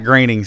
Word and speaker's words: Groening: It's like Matt Groening says his name rhymes with raining. You understand how to say his name. – Actually, I heard it complaining. – Or complaining.